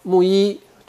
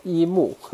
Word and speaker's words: 木一: Hello，木一，这回我知道怎么念了。原来一直看的拼音念念一木，也发现是木一。